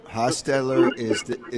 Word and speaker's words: Hostetler: Did I pronounce his name right? Hostetler? – Hostetler is the correct name, and that was a quarterback controversy, and you are in the mix.